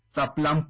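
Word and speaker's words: Ça plane: Bueno, la pronunciación de la canción es Zaplan pour moi. – Pero si es Zaplan, ¿por qué no hacen una S? – Porque pues las reglas y normas del idioma francés cambian respecto a nuestro hermoso español, incluso al inglés.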